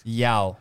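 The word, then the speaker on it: João: Did you say Miao Felix? – Yao. – Yao, he's pronunciation.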